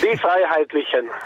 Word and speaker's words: Die Freiheitlichen: Leitner, lo dica lei, per favore. – Die Freiheitlichen. – Perfetto, la pronuncia è 10.